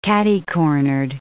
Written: catty-cornered

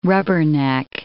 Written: rubberneck

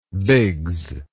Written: biggs

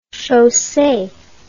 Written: chaussee

